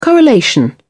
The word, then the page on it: correlation: British English